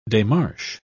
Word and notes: demarche: British English

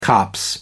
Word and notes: copse: American English

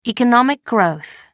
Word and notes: economic growth: American English

